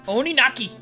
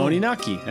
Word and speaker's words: Oninaki: Oh, I said it wrong. Oninaki. – Boom. Oninaki, that's right.